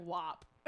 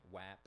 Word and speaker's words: WAP: No, well, because ass WAP is pronounced like with an "an," not an "ah." – I don't think. – Right, WAP.